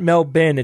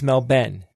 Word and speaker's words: Melbourne: Then we'll be going over to Melbourne. And I was corrected. It's not Melbourne. It's Melbourne. Melbourne.